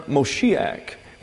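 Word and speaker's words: Moshiach (משיח): Anglicization of the Hebrew word Moshiach. That's M-O-S-H-I-A-C-H, pronounced Moshiach.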